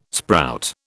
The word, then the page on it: sprout: British English